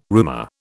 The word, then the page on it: roomer: British English